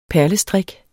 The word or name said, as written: perlestrik